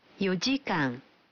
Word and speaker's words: 4時間: Faites attention, en revanche, certains nombres sont prononcés différemment. 4 heures se dira 4時間